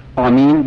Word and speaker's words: آمین: آمین بدون تشدید، آمین با تشدید لغت فصیحش نیست، آمین بدون تشدید باید تلفظ بشه، به معنی استجب است.